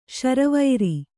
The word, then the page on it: ಶಾರ್ವರಿ: śarvari